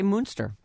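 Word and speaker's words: Muenster: M-O-O or M-U-N? – M-U-E-N. How would you say that? – Okay, Munster. Yeah. – Right? – Like the cheese, kind of.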